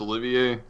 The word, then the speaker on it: Olivier: You mean Olivier? Like Laurence Olivier? Yeah. Is that how the character's name is pronounced? – Yeah.